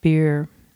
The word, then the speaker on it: Beerz: It's the name of it is, it's spelled B-E-E-R Z, and that's pronounced in Dutch as beer.